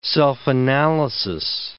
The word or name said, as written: self-analysis